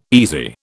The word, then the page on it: easy: British English